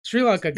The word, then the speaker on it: Sri Lanka: Sri Lanka. – Sri Lanka.